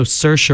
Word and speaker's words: Saoirse: Also, Saoirse Ronan was in that. She was very good in it. – Is that how you pronounce that? – Yes.